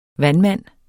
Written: vandmand

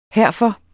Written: herfor

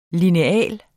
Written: lineal

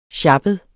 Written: sjappet

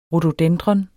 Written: RHODODENDRON

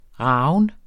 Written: ragen

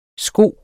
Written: sko

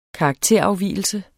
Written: karakterafvigelse

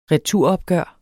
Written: returopgør

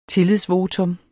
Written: tillidsvotum

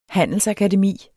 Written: handelsakademi

